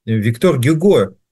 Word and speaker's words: Гюго: Вернее, как правильно произносить его имя? Виктор Гюго. Итак, Виктор Гюго считал: «Наша жизнь – путешествие, идея – путеводитель, нет путеводителя, и все останавливается», считал Виктор Гюго.